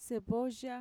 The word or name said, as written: sevoya